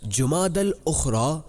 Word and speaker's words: جمادی الاخرى: درست نام اور صحیح تلفظ جمادی الاخرى ہے۔